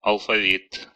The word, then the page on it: алфавит: [ɐlfɐˈvʲit]; /əɫ.fɐ.ˈvʲit/